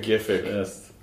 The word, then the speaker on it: GIF: To GIFIC. – I know it's pronounced GIF, but... – I say GIF. – I say GIF, too. – It's graphics interchange format. That's a hard G.